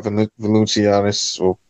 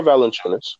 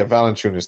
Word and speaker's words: Valanciunas: You still got Valanciunas. I know I butchered that name, whatever his name is. – Or Valanciunas. – Yeah, Valanciunas. Thank you.